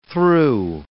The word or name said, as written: through